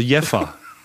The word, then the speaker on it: Jever: Und jetzt wurde mir geschrieben, nein, Jever wird tatsächlich nicht Jever ausgesprochen, ja. sondern das äh, V wird wie ein F gesprochen, also Jeffer. ja, genau.